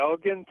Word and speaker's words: Elgin: Is it Elgin? Elgin? – Yeah, Elgin, Texas. That's how we pronounce it down here.